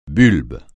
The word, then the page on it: bulbe: francais